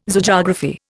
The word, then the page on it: Zoogeography: Indian